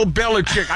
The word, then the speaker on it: Belichick: No, do they pronounce it like that? – Yes, always. – Do they? – Who cares? That's his name, Bill Belichick.